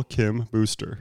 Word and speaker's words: Kim Booster: Was, and, the way you say his name, the whole time I thought it was Joel Kambusser. You pronounce vowels different than most people. Joel Kim Booster.